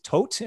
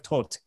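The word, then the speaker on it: Toht: His character's name is Tote in the movie. It's T-H-O-T. I think it's pronounced Todd in a way. Yeah, but it's like Tote in German.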